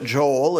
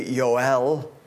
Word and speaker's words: Joel: That is the modern pronunciation of it, Joel. It's actually Yoel.